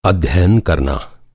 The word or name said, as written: अध्ययन